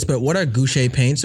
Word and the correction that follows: gouache: Do you mean gouache? – Oh, yeah. – Guacamole. – I told you I was going to butcher it. – Definitely sounds like gouache.